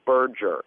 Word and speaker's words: Berger: Now, buddy, you said my name wrong a couple times now. It's Berger. – Berger. – Like merger with a B.